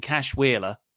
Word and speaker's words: Cash Wheeler: but it cuts to the crowd, but not the crowd at ringside, the stands, where it's just Dax Harwood and Cash Wheeler. Did I say that right? – You did, yeah.